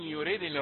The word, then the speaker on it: يريدني: مراجعة يسيرة بالنسبة للوقف، إن يريدني الرحمن، إذا وقفت إن يريدني، وإذا وصلت في اللغة عندنا إذا تكلمنا يمكن أن تكتب بنون بعدها ياء يريدني، ولكن لا يكون، ولكن النطق يعني تكون الياء موجودة ولكن لا تنطق بسبب التقاء الساكنين، ده هذا في اللغة عندنا، لكن هنا بعدها لفظ الرحمن فلا بد من حذف صوت الياء، إن يريدني الرحمن، سواء رسمت بياء بعد النون يريدني او بنون واحدة كما هو الرسم الان.